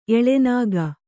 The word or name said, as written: ಎಳೆನಾಗ